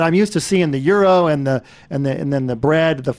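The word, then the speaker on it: gyro: How do you say it in the right way? – You hit it on the button. It's a euro.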